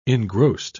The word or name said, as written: engrossed